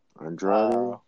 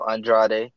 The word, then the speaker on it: Andrade: Andrade. – Yeah, there you go, Andrade.